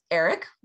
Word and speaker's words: Erryk: And one is Eric, which for us would be like E-R-I-C, but they spell it E-R-R-Y-K. And the brother is Arik, A-R-R-Y-K.